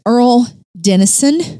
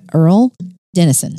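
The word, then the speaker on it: Earle Dennison: Earl Dennison. – Is that an Earlene? – No, it is not. – Just says Earl. – All right. – E-A-R-L-E, Dennison.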